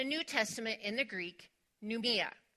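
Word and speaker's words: pneuma: So it's pneumia. And the way that that is spelled, just for those of you who like those things, is P-N-E-U-M-A. P-N-E-U-M-A, pneumia.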